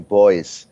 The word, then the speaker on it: Beuys: What I've sort of settled on is sort of saying Boyce.